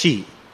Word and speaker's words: Qi: He's a Chinese, and of course, the word Chi, it's actually spelt as QI. It's not CHE. It is QI, but it is pronounced as Chi.